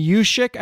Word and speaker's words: Juszczyk: Uh, props to uh, Kristin Yushik. I believe is how you pronounce her name. Yushik.